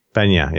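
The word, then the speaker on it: Peña: But I do want Michael Pena. Am I saying his name right? – Pena, yeah.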